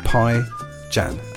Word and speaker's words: Pijan: Next group up are called Pijan, which seems right. I want to ask them how to pronounce their name, which is really oddly spelled. They said Pijan, as in pies in January.